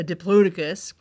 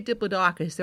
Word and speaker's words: Diplodocus: What they were talking about was a life-size model of a Diplodocus, but you can also say a Diplodocus. There are actually several pronunciations that are considered acceptable for this name, the name of this dinosaur.